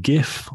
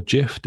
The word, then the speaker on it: GIF: You love a good GIF or JIF, depending on your pronunciation. – I do. I really do. That is correct. I also like to pronounce it as JIF because I think it sounds funnier.